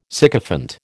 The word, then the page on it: sycophant: American English